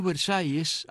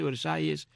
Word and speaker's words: Versalles: Ahora bien, estos Versalles, así como suena, o sea, V-E-R-S-A-L-L-E-S, que es como decimos Versailles, que es como es en francés, acaso, dispensa mi pésimo francés, que se escribe Versailles, que así es como se llama la barriada de Matanzas. Pasa que todo el mundo dice Versailles, Versailles, Versailles, Versailles.